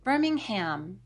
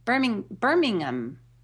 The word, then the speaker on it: Birmingham: Birmingham. – Well, not anymore, you won't. – Birmingham. Birmingham. – There you go. – But it's... It's H-A-M. You pronounce H-A-M, Hum.